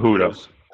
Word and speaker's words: Cejudo: So the next fight, is Henry Cejudo versus Dominic Cejudo. – Cejudo. Sorry. I should have known that J is silent.